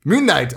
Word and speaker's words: Moon Knight: So you'll never see the majesty that is the Moonlight. Moon Knight. I said it wrong. Moon Knight.